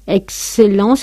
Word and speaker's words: excellent: Después, esos otros fonemas, K, S, G, Z, X, excellent, se marca muchísimo la pronunciación en francés. Excellent, suena K, S, accident, fixé en prix. Es fácil de pronunciar, simplemente exagerando esa pronunciación de KS.